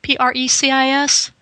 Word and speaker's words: Precis: P R E C I S? I'm not sure how you would pronounce that. – Precis? Yeah.